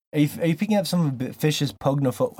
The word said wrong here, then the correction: pogonophobia: How do you pronounce that? Pognophobia? Is that what it is? – Pogonophobia. – Pogonophobia.